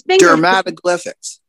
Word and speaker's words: dermatoglyphics: He continues new research, uh, particularly within the field of dermatol- dermatoglyphics. Did I pronounce that right? It's finger- dermatoglyphics. – Dermatoglyphics. Thank you.